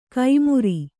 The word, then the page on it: ಕೈಮುರಿ: kaimuri